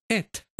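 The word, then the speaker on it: et (את): Esta palabra, que hoy en día en hebreo moderno se pronuncia et, no tiene traducción a ningún idioma.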